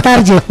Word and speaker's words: target: aquí está el target, no, es target, en inglés se pronuncia target.